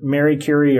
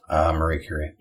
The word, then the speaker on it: Marie: Is it Mary Curie or Marie Curie? – Marie Curie.